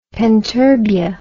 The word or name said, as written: penturbia